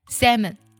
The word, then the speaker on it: salmon: 但其实这个单词当中啊，字母 L 是不发音的，它读作 sa 加门，就你直接把那个 L 去掉就行了。 salmon。